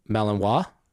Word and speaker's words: Malinois: Malinois. – How do you spell it? – Malinois. – Okay. So it's French. – R-I-N-O. – So if it is an actual Belgian dog, it's French. So Malinois.